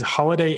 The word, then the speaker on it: Holladay: Yes, it is Holiday, H-O-L-L-A-D-A-Y. – Holiday. It's like Holla Back Girl, but with Holiday.